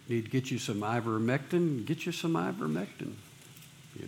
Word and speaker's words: ivermectin: I-V-E-R. – M-E-C. Am I not saying it right? Am I saying it wrong? – Yeah, you're saying it right. Ivermectin.